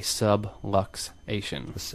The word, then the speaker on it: subluxation: We call them the misaligned vertebra that puts pressure on a nerve is called a subluxation. – A subway station. – Rhymes with subway stations. It sounds like that.